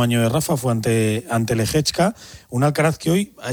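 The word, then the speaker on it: Lehečka: A las 11 de la mañana, hora española, la final de Tokio ante Lehechka.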